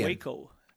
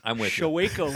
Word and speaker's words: Waco: Waco. – The show. Space. Waco. – You didn't say it like that. – Oh, really? – You said, it made me think of Showeco. Like, what's Showeco? – Showeco, it's an interesting drink.